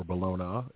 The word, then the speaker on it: Bologna: The 24th brings us National Bologna Day, or Bologna, if you want to pronounce it correctly.